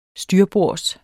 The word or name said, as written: styrbords